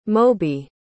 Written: moby

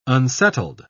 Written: unsettled

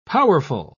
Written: powerful

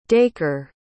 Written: dacre

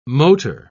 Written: motor

